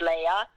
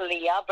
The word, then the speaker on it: Leah: Well, you have to think of Star Wars, like Princess Leia, so that's how you pronounce it. It's Leia, not Leah, but I'm not super sensitive, so even if you call me Leah, I'll still answer you.